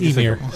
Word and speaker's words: Ymir: It's Ymir. Well, because she names him after Norse giants, and the Norse giants are called Ymir. And so, thank you for pronouncing it, because I didn't know. – it is Ymir.